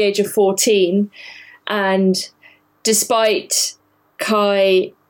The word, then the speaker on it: Kaj: It doesn't really explain why Kai or Kaj, I think they're pronouncing it Kai in the Danish, but who knows how it's pronounced. It's certainly written Kaj.